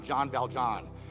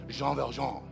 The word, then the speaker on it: Jean Valjean: It's not Jean Valjean, it's Jean Valjean.